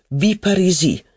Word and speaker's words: Villeparisis: Il discorso in cortile era caduto appunto su Madame de Villeparisis. Mi ha detto che è sua zia. Lui pronuncia Viparisi.